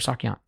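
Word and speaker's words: sak yant: One, you can get a yaksan, which is yaksan or sakyan? – Sakyan. – I knew I said it wrong.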